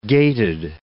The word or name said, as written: gaited